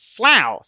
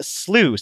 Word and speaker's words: slough: "I think it's pronounced slough, sounding like cow," Conscience said. "But the original pronunciation is slew, sounding like I slew you," Book said.